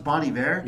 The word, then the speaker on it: Bon Iver: Somebody can correct me, but I think it's pronounced Bon Iver.